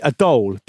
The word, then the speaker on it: dhole: A dhole. – D-H-O-L-E, a dhole.